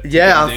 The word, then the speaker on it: Guendouzi: Yeah, Guendouzi.